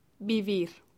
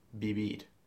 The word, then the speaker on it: vivir: Vivir is spelt with a V, V-I-V-I-R. But the Spanish don't pronounce it like we do. They don't say vivir. They pronounce it more like a B, like vivir, vivir. – Vivir. – Vivir. So it's actually a little bit in between B and a V. It's quite a soft B.